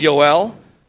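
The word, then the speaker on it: Yoel (יואל): So let's look at the name Joel, all right, pronounced in the original Hebrew as Yoel, because there was no letter J in the Hebrew alphabet, and it means Yahweh is God, or YHWH in caps, the tetragrammaton, the four-letter word of God, Yahweh is God.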